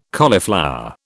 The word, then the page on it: cauliflower: British English